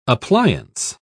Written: appliance

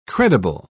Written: credible

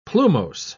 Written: plumose

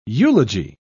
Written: eulogy

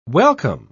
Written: welcome

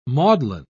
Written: maudlin

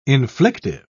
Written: inflictive

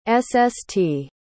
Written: sst

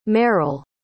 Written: meryl

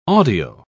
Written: audio

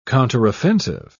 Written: counteroffensive

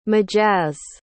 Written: majazz

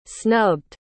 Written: snubbed

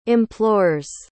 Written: implores

